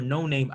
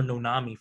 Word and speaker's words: No Name: So the rapper No Name, I was calling her Nonami forever.